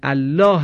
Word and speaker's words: الله: اما تنها در لفظ جلاله الله هست که لام تفخیم میشه، به شرط این که قبل از حرف لام جلاله حرکت فتحه و یا ضمه باشد.